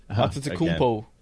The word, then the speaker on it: Antetokounmpo: One of my favorite facts about this whole thing is all three Antetokounmpo brothers. – Yeah. – I've butchered the name. – Antetokounmpo.